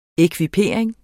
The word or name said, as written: ekvipering